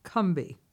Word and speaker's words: Combahee: Well, the Combahee River, which I have recently discovered, is actually pronounced Combahee. – Combahee. – Not three syllables, but only two.